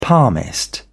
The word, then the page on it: palmist: British English